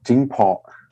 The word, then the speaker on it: Jingpo: One of them was pronounced Jingpo. – Okay. – It's Jingpo.